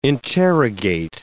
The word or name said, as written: interrogate